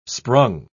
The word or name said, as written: sprung